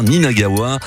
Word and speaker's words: Ninagawa: Alors oui, je le dis bien, Ninagawa.